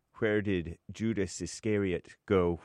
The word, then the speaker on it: Iscariot: What did Judas Iscariot, how you say it, James? – Iscariot. – What? – Iscariot.